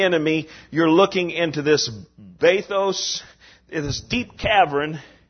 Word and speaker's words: Bathos: Bathos, B-A-T-H-O-S, long O,